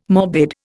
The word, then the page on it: morbid: Indian